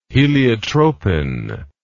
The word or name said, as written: heliotropin